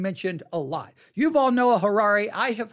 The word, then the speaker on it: Yuval Noah Harari: Yuval Noah Harari. Yuval Noah Harari. Yuval Noah Harari. Yuval is spelled Y-U-V-A-L. Yuval Noah, N-O-A-H, Harari, H-A-R-A-R-I.